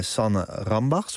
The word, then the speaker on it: Sanne Rambach: En Sanne Rambach, spreek je dat zo uit? – Mm-hmm. Ja? Ja.